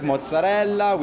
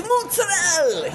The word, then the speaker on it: mozzarella: Well, how do you say it? – Mozzarella. But there's an A at the end. – Yes. – So it's a combination of both. So mozzarella. – Yes, make it both together and becomes mozzarella.